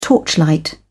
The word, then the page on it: torchlight: British English